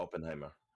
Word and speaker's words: Oppenheimer: Yeah, Oppenheimer.